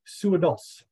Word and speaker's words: Suhadolc: It wasn't the worst, Stuart, but when you have a last name like mine, it gets massacred all the time. It's actually pronounced Suhadolc.